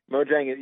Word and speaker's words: Mojang: The game's developer, Mojang, is that how I pronounce that right? – Mojang, yeah, was the name of the producing, you know, kind of team that was bought by Microsoft a couple of years ago.